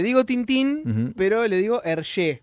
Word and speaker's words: Hergé: Y que me mienten la plana los francófilos y que me digan, no, sapo, se pronuncia Hergé, se pronuncia Hergé.